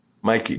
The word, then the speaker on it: MYQ: You pronounce it Mickey. Is that right? – Mikey.